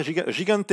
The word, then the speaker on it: Gigante: Et chez les femmes, victoire de Sarah Gigante, je prononce bien, Guillaume Ouais, c'est bon. C'est bon.